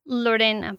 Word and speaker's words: Lorena: And Lorena Opplinger? – Lorena, but yes. – All right, fantastic. – You did not roll your R.